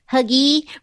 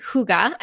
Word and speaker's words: hygge: Have any of you guys heard of that hygge, which is spelled H-Y-G-G-E? So for the longest time, I thought it was like huggy, which I think is kind of better than hygge.